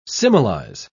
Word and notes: similize: British English

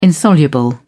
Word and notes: insoluble: British English

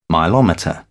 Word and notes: milometer: British English

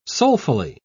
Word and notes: soulfully: British English

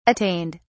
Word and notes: attained: British English

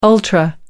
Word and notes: ultra: American English

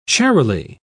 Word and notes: charily: British English

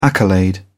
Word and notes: accolade: British English

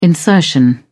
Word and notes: insertion: British English

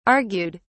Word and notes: argued: British English

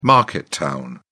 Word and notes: market town: British English

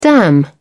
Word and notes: dam: British English

